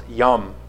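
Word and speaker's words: yum (ים): Some speculate that it has something to do with the Hebrew word for sea, which is pronounced yum, and the ancient Near Eastern myth of a divine warrior who conquers the forces of chaos represented by the sea.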